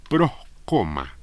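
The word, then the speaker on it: Proscoma (πρόσκομμα): La palabra tropiezo es una palabra griega que se escribe proscoma, con K y S, ¿no?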